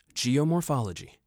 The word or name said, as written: Geomorphology